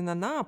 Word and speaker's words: Нана: Но, например, у Золя есть э, роман «Нана», ну, мне ужасно хочется сказать Нана, но, как я понимаю, правильно Нана, потому что у французов ударение на последний слог всегда ставится, хоть это и напоминает название э, поп-группы из 90-х.